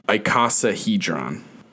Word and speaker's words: icosahedron: It is called an icosagon. I'm definitely mispronouncing that. – If it is a regular polyhedron made from equilateral triangles, which this certainly is, then it is an icosahedron.